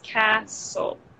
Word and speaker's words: castle: We don't say the T. It's castle. Castle.